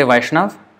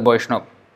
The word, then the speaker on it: Vaishnav: B and V is the same. So it can be pronounced as this way or that way. So it is not wrong to say Vaishnav, but it can also be pronounced as Boishnob.